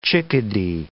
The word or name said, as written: chickadee